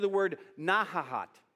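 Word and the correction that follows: Nahalat (נחלת): Not not a lot, but sounds like that. Nahalat. N A, let me spell it for you, N A H A L A T, not halat.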